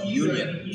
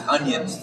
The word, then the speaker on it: Union: And so she bought my sister and I 5-speed bicycles, and they were from a company called Royce Union. Of course, that's UNION. – Yeah. – And I pronounced it onion back then. Lots of arguments with people. No, it's onion. It's not union.